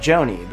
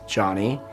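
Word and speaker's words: Jony: You know, even though it's spelled J-O-N-Y, I correct them, but they're someone to pronounce my name like Joni. But if I were to tell you over and over again, my name is Johnny, and you insist on mispronouncing my name, I would feel disrespected by you.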